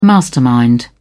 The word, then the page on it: mastermind: British English